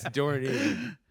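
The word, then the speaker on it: dohrnii: Dornier. – No, it's dorny.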